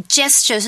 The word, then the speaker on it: gesture: Gesture 是什么呢？注意哦， gesture 是个 G 开头的，可是我们是念 soft G 的 sound, gesture, okay?